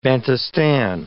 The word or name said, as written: Bantustan